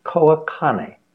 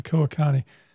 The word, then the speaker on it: Koa Kane: How do you pronounce his name, by the way? – It's Koa Kane. Okay, Koa Kane.